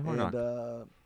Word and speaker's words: Hornok: Hornock. I'm probably pronouncing it wrong, but H-O-R-N-O-K. – Yeah, Hornock.